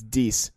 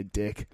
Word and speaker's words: Dix: It's spelled D-I-X, so I was going to say Dick. – Dicks. Episode Dicks, boy, let's go. – But it's D. It's pronounced Dice, so...